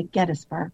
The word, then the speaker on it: Gettysburg: at Gettysburg, the locals say Gettysburg. – Gettysburg.